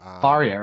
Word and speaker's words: Faria: Faria, right? – Yeah.